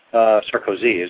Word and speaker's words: Sarkozy: And the French President Nicolas Sarkozy, Sarkozy is how he pronounces it, I believe, says he's considering a plan to install more cameras on public transit also.